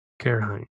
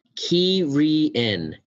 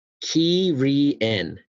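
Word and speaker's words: Cairhien: Oh, here it is. Pronounced Kee-ree-en. – Wow, we were way off. – Kee-ree-en.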